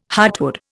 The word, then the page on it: heartwood: Indian